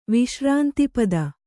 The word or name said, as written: ವಿಶ್ರಾಂತಿಪದ